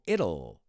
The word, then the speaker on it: it'll: It will, it'll. Like Italy. I always think of Italy.